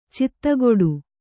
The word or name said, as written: ಚಿತ್ತಗೊಡು